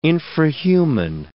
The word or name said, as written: infrahuman